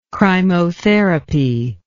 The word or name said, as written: crymotherapy